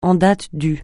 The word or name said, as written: en date du